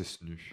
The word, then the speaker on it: SNU: Oui, on peut prononcer SNU.